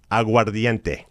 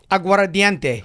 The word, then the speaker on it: Aguardiente: You got to say it properly. Aguardiente. – Aguardiente. – Oh, muy bien. Yeah, right, perfect. Good.